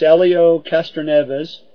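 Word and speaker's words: Helio Castroneves: Uh, here's a picture of the Indianapolis 500 with Helio Elio. Uh, I always pronounce that wrong. It's Elio Castroneves crossing the finish line at the Indianapolis 500.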